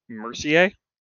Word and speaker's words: Mercier: I actually should have asked how to pronounce your username, but I'm going to go with Mercier. – That's the Canadian way to pronounce it.